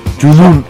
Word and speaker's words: Junun: And then Junun by, how do you pronounce it? – Junun.